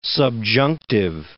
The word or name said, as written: subjunctive